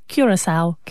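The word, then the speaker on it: Curaçao: Curacao. – Curacao.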